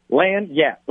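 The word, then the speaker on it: lagniappe: It's just Lanyap. It's two syllables. Lanyap."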